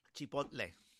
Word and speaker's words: Chipotle: And today I went down to Chipotle or whatever, however you pronounce it. – Chipotle. – Chipotle.